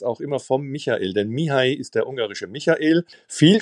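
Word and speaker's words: Mihály: Also, ganz langsam, Mihai. Dieses LY wird im Ungarischen Ji ausgesprochen. Cheek, weil auf dem I ein Apostroph ist, wird der lang ausgesprochen.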